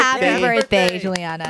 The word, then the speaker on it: Jewelyana: Oh, it's Juliana. – Yeah, that's, well, I like the way she spelled that. – Tell everyone how it's spelled. It's spelled like Jewel. – J-E-W-E-L-Y-A-N-A.